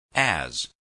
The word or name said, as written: as